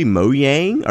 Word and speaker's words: Mojang: Is it literally Mojang? Are they Mexican? – No, they're Norwegian. – So the J is pronounced like a Y in Norway is like Yeb.